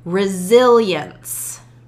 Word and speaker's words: resilience: Grit, resilience, has a dark L in there, zillions, and kind of this invisible Y in there, resilience.